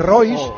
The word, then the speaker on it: Rois: Pero se escribe decir. Royce, R-O-I-S. – Sí. – Vale, da igual. – Ese en francés, Roa.